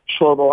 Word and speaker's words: Shlomo: It's Shlomo Argali, who's a tenured professor at IIT.